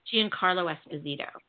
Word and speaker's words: Giancarlo Esposito: Giancarlo Esposito.